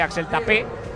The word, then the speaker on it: Axel Tape: Siendo francés, no sabía si era Tay, como has dicho tú, si era Tape o si era Tape, y he escuchado a periodistas de radio en Francia hablando de Axel Tape, que es como se pronuncia correctamente el fichaje, que ha cerrado ya, este cerrado en Levante, como dice Alemán, cedido una temporada procedente del Bayer Leverkusen.